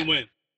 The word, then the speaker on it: Nguyen: It's like using like a Vietnamese letter that is pronounced differently that Americans don't even like. It's very like throatal. So whichever works. – Nguyen.